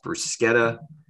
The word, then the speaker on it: bruschetta: However, in Italy, the CH sound makes this K sound. So bruschetta is pronounced bruschetta.